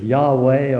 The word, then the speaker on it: Yahweh: His name is Jehovah, or that's how some pronounce it. Others would say we really don't know how to pronounce it. It's Yahweh, or the scholars don't seem to really know, and if they don't know, I'm sure I don't know.